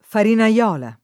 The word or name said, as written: farinaiola